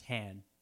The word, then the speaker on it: Han: Well, no, because it's pronounced Han. – Oh, yeah. Hand without a D.